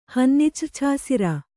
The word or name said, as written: ಹನ್ನಿಚ್ರ್ಛಾಸಿರ